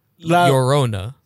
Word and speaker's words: La Llorona: La Llorna. La Llorona.